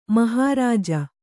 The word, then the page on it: ಮಹಾರಾಜ: mahārāja